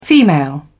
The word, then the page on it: female: British English